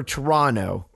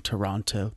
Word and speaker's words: Toronto: Don't you dare come on this podcast and not pronounce the second T in Toronto.